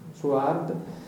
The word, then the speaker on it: Suhard: Poco più tardi, nel 48, c'è una lettera della Pontificia Commissione Biblica al Cardinal Suhard, Suhard, se non c'è l'H, okay, cioè l'H c'è ma non si pronuncia, Suhard, che riguarda i primi capitoli di Genesi, Genesi capitoli dall'1 all'11.